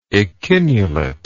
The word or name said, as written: echinulate